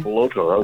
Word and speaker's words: Capeloto: Capeloto. That was great. Yeah, it was very good. Most people butcher it.